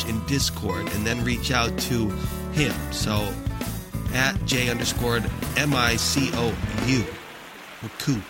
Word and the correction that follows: Micou: That's pronounced Michu.